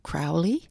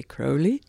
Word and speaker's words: Crowley: I know Americans say Crowley. Crowley. um, we say Crowley.